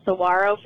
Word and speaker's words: saguaro: I think you said saguaro a long time ago, and that's saguaro for the cactus.